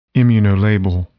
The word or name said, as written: immunolabel